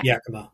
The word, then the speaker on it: Yakima: How do you say it? It's Yakima, right? – Yeah. Yakima.